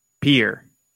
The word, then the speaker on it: Pierre: But the people in South Dakota pronounce it Pierre. – Pierre. P-E-E-R. But it's like P-I-E-R-R-E.